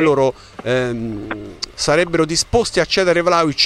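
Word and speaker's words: Vlahović: No, io non sarei per la cessione di Vlahovic, te lo pronuncio come so che andrebbe pronunciato, non per fare, perché ormai ho preso l'abitudine a pronunciarlo Vlahovic e quindi tutti mi criticano, ma sarebbe la pronuncia corretta.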